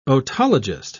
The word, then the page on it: otologist: British English